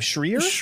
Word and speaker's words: Schreier: How do you say his name? Schreier. – Yeah.